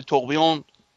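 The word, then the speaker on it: tourbillon: ره نه ببین تلفظی که میگم فرانسوی، شما میگم نوشتنی میشه توربیلون. – توربیلون توربیلون می نویسن، ولی توقیون تلفظ فرانسشه.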